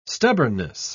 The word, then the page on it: stubbornness: British English